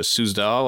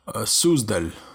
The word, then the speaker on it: Suzdal: For instance, the case of mass possession in 1666 in the, you pronounce this, Suzdal or? – Suzdal.